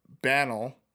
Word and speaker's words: banal: A New York Times reviewer found the book banal. Hold on. What does Bannel mean? And how do you pronounce it? B-A-N-A-L? – Banal.